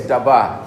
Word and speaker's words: dabar: And the word, therefore, the word of the Lord, the word is Dabar. Okay, Dabar. Now, it's spelled D-A-B-A-R. It's pronounced D-A-W-B-A-W.